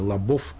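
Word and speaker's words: Labov: Вот есть такой знаменитый американский социолингвист Уильям Лабов, или Лабов, американцы его так вот с таким ударением произносят.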